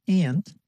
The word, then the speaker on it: aunt: ส่วนคนอเมริกันทางฝั่งตะวันออกเฉียงเหนือของประเทศนะคะจะออกเสียงคำว่าป้าว่า aunt ค่ะฟังตัวอย่างนะคะ People say the word aunt like aunt. Listen.